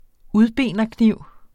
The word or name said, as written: udbenerkniv